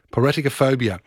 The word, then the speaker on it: Piraticophobia: Bassett, and it is The Fear of Pirates, pronounced Piratica, Piratica, no, hang on, Piratica Phobia. Piraticophobia is a thing.